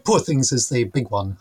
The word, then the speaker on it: Poor Things: And of course, it's Poor Things rather than Poor Things rhyming with Paw Patrol. Because it has to be very much it is a Glaswegian title.